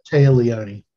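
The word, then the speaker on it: Téa Leoni: Going back to Karen Allen, I think her voice like sounds a lot like, is it, I can't, if I'm pronouncing this correct, but Taya Leone, how you say it? – Taya Leone. – Taya Leone, how you say it? – Taya Leone. – Taya Leone, yeah.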